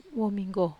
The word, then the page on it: wominggo: Wamesa